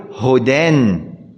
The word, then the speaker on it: هدى: ماذا تقول؟ هدى أو تقول هدن؟ أقول لك اكتب هدن، كما تلفظها اكتب لي هدن، ماذا تكتب؟ تكتب هاء دال ونون، هكذا تلفظ، هدن.